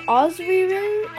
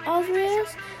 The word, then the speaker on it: Ozyrys: How it's spelled is O Z R, no, O Z Y R Y S, but I pronounce it Ozrius, Ozrius.